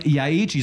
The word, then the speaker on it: Yaeji: Jetzt aber gibt's Musik auf Ohren, wie schon angekündigt, von Yaeji. So spricht man's aus, oder? – Yaeji. – Ja, gut. – Ja, Yaeji.